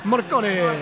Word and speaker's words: Morricone: Morricone.